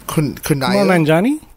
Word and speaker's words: Kumail Nanjiani: Kumail Nanjiani? – Yes.